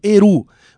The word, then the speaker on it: Erhu: O nome do CD Eru se escreve E-R-H-U. Eru.